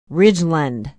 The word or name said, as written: Ridgeland